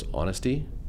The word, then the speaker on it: honesty: The fourth one was honesty, which if you don't know is a silent H.